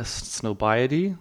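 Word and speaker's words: Highsnobiety: High snobri- uh, snobiety. – Yeah, that's what it looks like. – like yeah, like snob and yeah, it does sound like snob.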